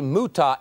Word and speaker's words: Muta: I say Muta, M-U-T-A. That is the correct pronunciation and spelling, I have been informed.